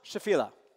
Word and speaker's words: Shephelah: It's pronounced Shephelah, Shephelah. How I'm going to probably pronounce it sometimes is Shephelah, because that's how I first heard it. That's so wrong. Or Shephelah. That's also wrong. Shephelah.